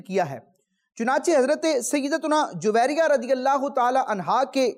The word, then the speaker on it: جویریہ: بعض لوگ اس نام کو یوں پڑھتے ہیں جویریہ، یہ تلفظ درست نہیں ہے۔ علماء کرام نے اپنی کتابوں میں اس نام کا تلفظ یوں ذکر کیا ہے، جویریہ۔